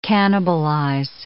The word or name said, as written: cannibalize